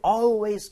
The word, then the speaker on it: always: 자, 이거 발음 여러분 아시겠지만 always가 아니라 always. 아까 전에도 제가 ALL, call me 한 거랑 마찬가지입니다. 그래서 always 이렇게 하지 마세요. 입모양 벌려서 해주세요.